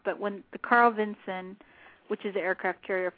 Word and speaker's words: Vinson: they kept pronouncing the name wrong. Like the USS Carl Vinson's, the Carl Vincent, which I was guilty of saying that a lot, Craig would say. It's not Vincent, it's Vinson with the S-O-N.